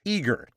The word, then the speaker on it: EEAGER: EEAGER, as in eager beaver, by the way.